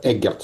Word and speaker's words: Эггерт: Номер два, моя фамилия произносится Эггерт с ударением на первый слог.